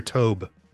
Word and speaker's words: Tobe: I always wondered Tobe because it's T-O-B-E, so it could be Tobe, you know, long O.